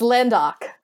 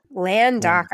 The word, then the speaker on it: Landok: It's Landok. – Landok. I think I've got Ledunk written down every time in my notes, but I don't know his full name.